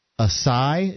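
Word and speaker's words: açaí: Acai is how it's pronounced.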